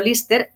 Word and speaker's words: Liste: Liste, pronunciado Lister, en acción.